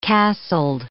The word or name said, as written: castled